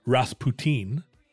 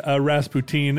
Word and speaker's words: Rasputin: Well, it turns out we say Rasputin, but in a European context, in German, the name is Rasputin.